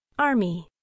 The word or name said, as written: army